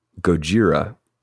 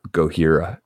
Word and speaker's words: Gojira: So it is Gojira, not Gohira.